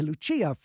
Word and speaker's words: Lucia: So it must be Lucia. I'm sorry, Lucia from North Hollywood. I have to get that the Italian pronunciation.